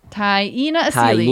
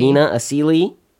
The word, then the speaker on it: Taina Asili: Taina Asili. – Taina Asili.